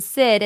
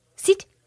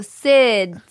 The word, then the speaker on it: Sid: Okay, every time I call the nail salon, I guarantee you, they like I say my name, Sid, and they go, Sid. I'm like, no, Sid.